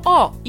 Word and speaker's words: ho: Zum Beispiel, io ho, ich habe, man schreibt O nicht nur mit O, sondern auch mit H am Anfang. Io ho. Aber die Italiener sprechen nicht das O aus.